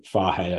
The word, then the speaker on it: Fage: Proper Greek yogurt, so like total fahe, I think is how you say it, F-A-G-E, or skia, I don't know how you say that one.